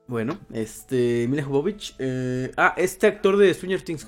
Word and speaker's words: Jovovich: Oye, Mila Jovovich, sí, o Jovovich, no sé cómo se dice. – Sí, Mila Jovovich.